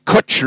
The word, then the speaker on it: Cwtch: The Kutch Room, K-U-T-C-H. That's not how you spell it. That's how you see it. Kutch. It's a Welsh word. It's spelt C-W-T-C-H.